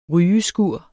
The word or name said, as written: rygeskur